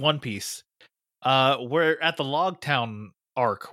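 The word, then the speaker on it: Loguetown: Because it's spelled L-O-G-U-E town. So, with my cursory knowledge of Japanese and their inability to say the L, I always thought it was Rogue Town. And I thought it was And I thought it was pronounced Rogue Town in the 4Kids dub, but I could be wrong. But they just keep pronouncing it Log Town, and I'm just like, why?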